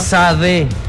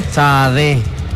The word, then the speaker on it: Sade: Sí, es Sade. – ¿Cómo? Sade. – Sade. Así.